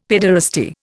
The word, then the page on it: pederasty: Indian